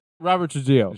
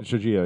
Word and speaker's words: Trujillo: Robert Trujillo. – Trujillo, yeah.